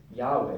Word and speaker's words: Yahweh: Jehovah was kind of like this confusion with the J and the Y, but it's Yahweh. That's how you pronounce his name.